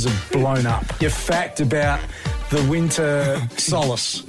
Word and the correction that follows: solstice: The solace, you said. – Yeah, no, I said the solstice. – You said the solace. – S-O-L-T-I-C-E. Correct? – How do you spell it? Sorry? – Oh, I've just bloody told you. S-O-L-T-I-C-E. – No, it's the solstice. Yes.